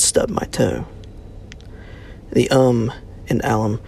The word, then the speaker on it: Alum: Alum is not pronounced like you were referring to your alma mater. The Al is pronounced like the common nickname. Imagine your dad's brother is named Albert, and your mom grits her teeth whenever anyone mentions Al. That kind of Al.